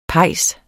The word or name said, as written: pejs